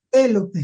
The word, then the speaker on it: Pélope: Espero haberlo pronunciado bien. – Pelo, pues sí, pelo. – Pelope, el Sí. – acento en la primera.